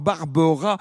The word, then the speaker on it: Barberaz: Alors, Barbera, il y a un Z à la fin du nom, mais on ne le prononce pas. Hein. Ça s'écrit B-A-R-B-E-R-A-Z.